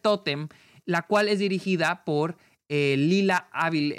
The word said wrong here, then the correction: Avilés: eh, Avilés, con acento en la Avilés, Lila Avilés, y